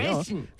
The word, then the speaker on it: fasten: Fasten. – 그래서 fasten your seatbelts. – Fasten your seatbelt. – 네, 그 아까 fasten, F-A-S-T-E-N 여기서 T 발음은 안 들어갑니다, 여러분.